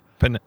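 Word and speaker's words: piñata: In the Panania. – Pinata. – It's pronounced pinata.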